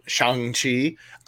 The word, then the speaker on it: Shang-Chi: And I know we need to wrap this part up, but I'm so excited about Shang-Chi, as I have now been corrected to pronounce it, because I love Simu Liu.